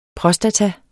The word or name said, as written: prostata